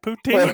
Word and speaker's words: poutine: Is that the right pronunciation? – Yeah, that's it, poutine.